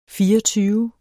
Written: fireogtyve

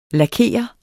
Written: Lakerer

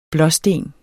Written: blåsten

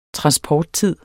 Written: transporttid

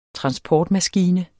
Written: transportmaskine

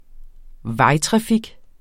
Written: vejtrafik

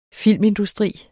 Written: filmindustri

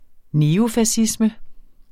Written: neofascisme

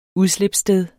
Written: udslipssted

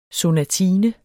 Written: sonatine

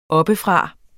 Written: oppefra

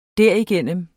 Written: derigennem

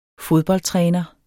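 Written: fodboldtrænere

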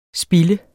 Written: spilde

